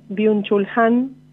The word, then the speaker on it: Byung-Chul Han: que es Byung-Chul Han.